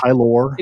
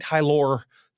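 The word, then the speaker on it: Tylor: The Mets announcers, by the way, definitely, I assumed it was just pronounced Tyler. It's T-Y-L-O-R, but the Mets announcers. It is Tyler. – It is, the way they pronounced it was definitely Tyler McGill, who started for the Mets on Sunday.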